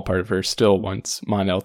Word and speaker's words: Mon-El: So it makes sense to say Mon sometimes, but usually pronounced Mon-El. That's how they've been saying it all season, but Cat Grant still says Kira.